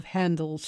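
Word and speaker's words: Händel: And some music now, George Frideric Handel, and the Italian title on this is Sonata del Signor H-E-N-D-E-L. And the reason that it was spelled that way is so that Italian people would pronounce his name properly. The proper spelling of Handel's surname is H-A-Umlaut-N-D-E-L. And of course, in German, it's pronounced Handel. And that was how they got it so that Italians would say it properly.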